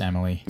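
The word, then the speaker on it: Emilie: It's starring Joseph Gordon-Levitt, Nora Zehetner, Lucas Haas, Noah Fleece, Matt O'Leary, Emil de Ravine. I think that's how you say her name. It's Emily or Emil? – I think it's just Emily. – Okay, fine. It's Emily.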